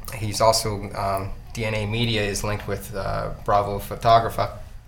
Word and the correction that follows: Fotografia: I said that wrong. Anyway, Bravo Fotografia, I believe.